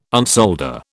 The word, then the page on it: unsolder: British English